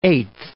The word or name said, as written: eighth